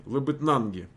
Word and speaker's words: Лабытнанги: Лабытнаги, ударение. Лабытнаги. Нанги. Лабытнанги, понял.